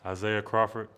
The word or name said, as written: Isaiah Crawford